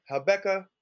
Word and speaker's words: Habakkuk: You know, we're at, I always pronounce it Habakkuk, but Habakkuk, I'm cool with that.